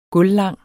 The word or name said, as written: gulvlang